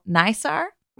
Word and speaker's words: NISAR: He previously has worked on the Mars Curiosity mission, Cassini, Europa Clipper, and now NISAR. Is that how we're pronouncing this? – Yeah.